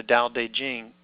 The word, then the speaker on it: Tao Te Ching: Tao Te Ching.